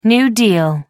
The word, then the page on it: new deal: American English